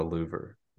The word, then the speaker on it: louver: I'm playing the pronunciation right now. I think it's called a louver, but let's see. – Louver. – Yes. Okay. This pronunciation says louver.